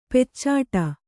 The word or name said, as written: ಪೀಚಾಡಿ